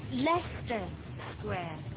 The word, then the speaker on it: Leicester Square: How do you pronounce it? – Leicester Square. Yes, this is Leicester Square.